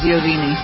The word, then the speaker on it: Iovine: Well, the real pronunciation of Italian is Iovine. – Yeah. Who asked you? – I'm just telling you, that's how we would be pronounced in Italian.